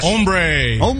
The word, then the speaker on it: hombre: Mr. Announcer Hombre, did I say that right, Bean? – Yes. – Hombre.